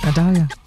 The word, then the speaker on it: Idalia: The thing that's happening now, I guess the hurricane Idalia. Is that how you say it? – That's how you say it, Idalia.